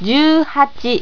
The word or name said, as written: juuhachi